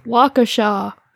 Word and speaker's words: Waukesha: It's pronounced Waukesha, and you said Waukesha.